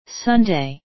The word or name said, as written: sunday